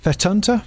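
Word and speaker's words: fettunta: How do you pronounce that the word? Fetunta? Fetunta? – Oh, fetunta, yeah. – Fetunta.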